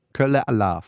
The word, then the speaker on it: Kölle Alaaf: Kölle Alaaf.